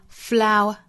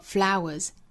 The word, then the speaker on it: flower: 在单词内部两个元音相遇会由前面一个元音的相似口型带出这个小小的 w 的发音 shower, shower, shower, shower. 和 flower, flower, flower, flower 这两个词后面加 s 都读浊辅音的 showers 和 flowers 清清浊浊的规律。